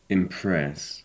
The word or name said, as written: impress